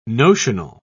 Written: notional

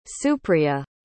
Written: supriya

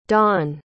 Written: donn

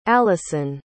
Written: allison

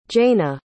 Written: jaena

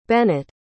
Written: bennett

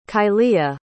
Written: kylia

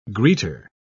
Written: greeter

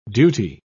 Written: duty

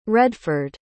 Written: redford

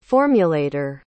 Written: formulator

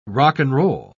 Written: rock'n'roll

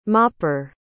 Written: mopper

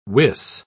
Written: wis

